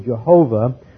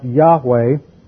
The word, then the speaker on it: Yahweh: And this is the Greek transliteration of the Hebrew sacred tetragrammaton, YHWH, which is transliterated wrongly, Jehovah, but accurately is probably pronounced Yahweh, which is the personal name of God and the covenant name of God, reflecting His covenant with Israel.